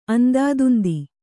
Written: ಅಂದಾದುಂದಿ

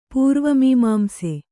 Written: ಪೂರ್ವಮೀಮಾಂಸೆ

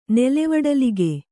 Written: ನೆಲೆವಡಲಗೆ

